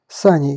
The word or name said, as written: Саней